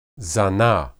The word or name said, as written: zanah